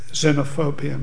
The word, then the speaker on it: xenophobia: We all know what xenophobia means, don't we? I take umbrage that the English decided to pronounce a clear Greek X as a Z, but that's another matter. Xenophobia.